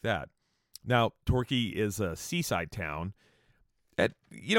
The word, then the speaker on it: Torquay: Okay, Torquay is spelled Torquay. How, as you know, a citizen of the United States of America, how would you pronounce that? Let me ask you. Torquay, right? Wouldn't you go, oh, Torquay, what an interesting name for a town. No, they say it Torquay.